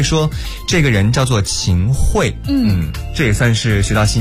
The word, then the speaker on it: 秦桧: 你知道吗，刚刚我查了一下，就是关于秦桧还是秦桧的这个读音，最后发现是应该念秦桧。